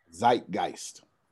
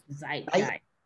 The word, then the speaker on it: Zeitgeist: Help me out with the pronunciation of that name. – Zeitgeist. – Zeitgeist, that's it.